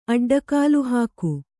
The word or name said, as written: ಅಡ್ಡಗಾಲುಹಾಕು